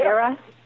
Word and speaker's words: Erra: Am I pronouncing that right? – Yeah. – Era? E-R-R-A?